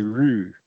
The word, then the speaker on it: Rooi: It's actually, yeah, they spell it with two O's, so you got kind of got to say Rue. – Yeah. – R-O-O-I. – It's the R-O-O-I.